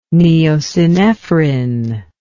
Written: Neo-Synephrine

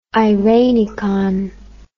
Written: irenicon

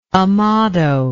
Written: amado

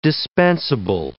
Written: dispensable

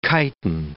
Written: chitin

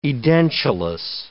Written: edentulous

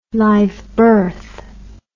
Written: live birth

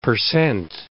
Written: per cent

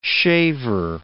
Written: shaver

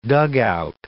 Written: dugout

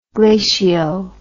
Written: glacio

